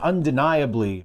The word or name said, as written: undeniably